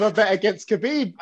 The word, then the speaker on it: Khabib: Khabib, that's it.